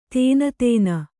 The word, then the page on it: ದೀನತನ: dīnatana